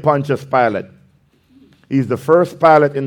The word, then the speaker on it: Pilate: Then Pilate, and they don't spell the same, but you know, that's how it's pronounced, Pilate. Then Pilate went back into his headquarters and called for Jesus to be brought to him.